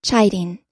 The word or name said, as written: chiding